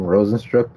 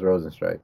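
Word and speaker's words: Rozenstruik: Um, I'm so confused because they pronounced his name Rosenstruck before, like in his previous fights, and now it's Rosenstrike.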